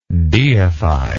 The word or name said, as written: deifier